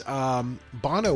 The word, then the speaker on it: Bono: I've been mispronouncing it this whole time. – Bono. – Oh, it is Bono.